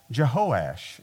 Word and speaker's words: Jehoash: We're going to be looking at this story when a king comes to him, and the king's name is Joash, or it's also, he's called Jehoash, and so it's either way.